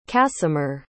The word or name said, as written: casimer